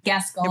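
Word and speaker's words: Gascón: Gascon. – It reminds me of Beauty and the Beast.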